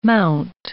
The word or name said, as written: mount